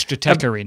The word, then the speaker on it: Stratechery: It's Stratechery now.